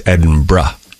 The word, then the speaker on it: Edinburgh: And she said it's Edinburgh. – Edinburgh. – Like B-R-U-H is the end. – Like Hebra. – Hebra. So not Edinburgh, not Edinburgh, not Edinburgh, Edinburgh.